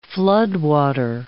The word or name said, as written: floodwater